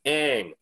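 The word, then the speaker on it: Ng: I did, in fact, have to Google how to pronounce her last name because it is spelled N-G. It is pronounced Ang.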